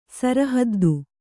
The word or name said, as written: ಸರಹದ್ದು